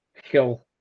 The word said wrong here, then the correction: hwyl: It's Hoyle. – Hoyle? – Mm. Hoyle. – Damn. Are W's O's? Is that how Welsh works?